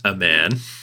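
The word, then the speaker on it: Aman: Now, I will say that I believe that his name is, and I am not joking, intended to be pronounced a man. As in a man.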